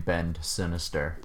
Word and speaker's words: Bend Sinister: But you gotta pronounce the D. Really gotta pronounce the D. So how did you guys end up getting the name Bend Sinister? – Well, it's interesting.